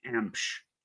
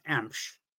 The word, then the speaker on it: Emch: My last name is pronounced Empsch. – Emp. Okay. – Well, and that's okay. EMC. It's pronounced Empsch. So if there was like a, if it was spelled EMPCH, that's how you pronounce it.